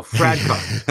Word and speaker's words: Fradkov: Fuckoff? Is that his name? Oh, Fradkov. Sorry, I thought it was Fuckoff.